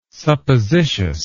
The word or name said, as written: suppositious